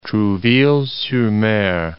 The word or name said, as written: Trouville-sur-Mer